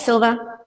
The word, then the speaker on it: Szilva: So, and it's pronounced Silva, S-Z-I-L-V-A, right? – Not Silva or Zilva, but yeah, I guess that's not to be known until you learn. So yeah, it's Silva, silent Z.